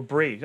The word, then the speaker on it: Brie: Is it Brie or Brie? Ah, it's spelled like the cheese.